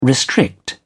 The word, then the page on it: restrict: British English